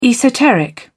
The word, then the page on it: esoteric: British English